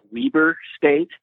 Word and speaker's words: Weber: Well, actually, here in Utah, we pronounce it Weber State. – Oh, my bad. – I think you're probably right, but here in Utah we call it Weber State for whatever.